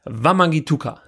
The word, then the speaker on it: Wamangituka: Ich sag's gar nicht mehr, wie wir es sonst ausgesprochen haben, sondern Wamangituka. So wird er richtig ausgesprochen, Sebastian.